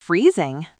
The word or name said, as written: freezing